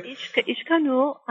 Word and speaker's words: Ixcanul: Ishkanu, is that right? – Ishkanu, I-X-C-A-N-U-L.